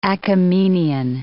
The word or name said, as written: Achaemenian